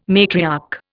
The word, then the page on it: matriarch: Indian